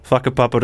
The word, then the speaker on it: Whakapapa: that's how you pronounce the name. – Sorry, that's okay. Not swearing. – What was it? – Whakapapa to Tūroa. Okay. it's a Maori word.